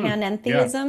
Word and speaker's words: panentheism: am I pronouncing mm, panentheism? Yeah.